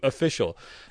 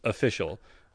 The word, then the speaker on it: official: Official. That's what I think you pronounce it as official. Uh, but I've heard people say official, like turn on and off.